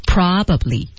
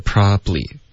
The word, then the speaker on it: probably: 哎，我们说这个单词的本来的官方的发音呢是 probably。 – 对，三个音节 probably。 Probably，然后它的实际发音在美式的发音用的比较多是 probably，中间的这个 b 的音节就给完全给省了 probably，像这种情况下这就被省了，所以他就觉得是不是还有别的单词也会这样呢？